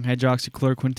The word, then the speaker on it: Hydroxychloroquine: of Iran is allowing hydroxychloroquine to be over the counter. South America, these countries now have more freedoms than us. – The in regards to the hydro, how to say it again? – Hydroxychloroquine.